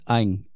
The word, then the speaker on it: Anh: All right, so for me, right, my name is Ang, A-N-H, but then people always mispronounce it with A-H-N, so they're like on or an, right?